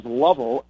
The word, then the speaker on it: Lovell: My last name is, well, it's Patrick, Patrick, Last name is Lovell, L-O-V-E-L-L, and then followed by the number one on Twitter.